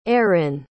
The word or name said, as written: eairein